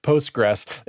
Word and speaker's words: Postgres: Is that how you say it? Postgres? – I hope so, because that's how I've always said it. – Yeah, okay. But we're rolling with it. Postgres.